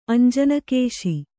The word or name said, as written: ಅಂಜನಕೇಶಿ